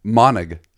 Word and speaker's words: Monnig: Monnig.